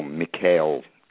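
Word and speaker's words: Michaël: Tim, it wasn't until earlier this afternoon that I was told that Michael Roskam, or Mikhail, excuse my mispronunciation there, was a painter.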